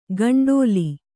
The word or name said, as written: ಗಂಡೋಲಿ